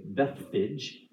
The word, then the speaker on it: Bethphage: Okay, so as Jesus came near Bethphage, I did look that up to make sure I pronounce it correctly, Bethphage.